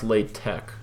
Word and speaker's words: LaTeX: Well, it's actually pronounced LaTeX. I bet you didn't know that.